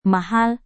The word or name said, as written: mahal